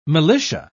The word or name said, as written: militia